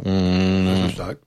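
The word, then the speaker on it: Graissessac: Alors, comment le prononce-t-on ? Grèce-Sac ou Grèce-Sac ? – Grèce-Sac.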